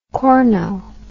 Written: corno

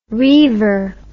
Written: reaver

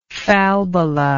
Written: falbala

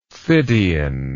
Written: Phidian

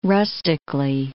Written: rustically

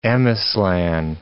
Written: Ameslan

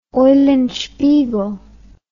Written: eulenspiegel

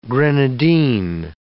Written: grenadine

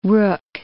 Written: rook

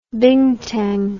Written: bintang

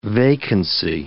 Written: vacancy

